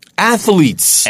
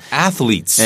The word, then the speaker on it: athletes: A-T-H 다음에 E가 없어요. A-T-H-E-L-E가 아니고요. A-T-H 바로 L이 붙어요. – You know who makes this mistake a lot? – 음? – Americans. – 맞아요. 미국 사람들이 여기다가 Because they 네. say it like this, athletes. – 맞습니다. Athletes. 그러니까 거기다 E를 집어넣고 싶어 한다고요. – But that's not correct. – 맞아요. – The correct pronunciation is athletes.